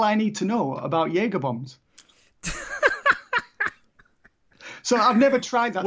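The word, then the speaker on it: Jägerbomb: Jaeger bombs, which have I pronounced that correctly? – Yeah, you've absolutely pronounced it correctly.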